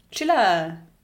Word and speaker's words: Csilla: Chilla. C-S-I-L-L-A. Mhm. Würde ich mal so aussprechen.